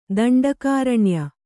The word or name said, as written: ದಂಡಕಾರಣ್ಯ